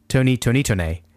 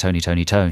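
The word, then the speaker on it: Tony! Toni! Toné!: Well, we used to enjoy saying Tony, Tony, Tony. But I think it's just Tony, Tony, Tone.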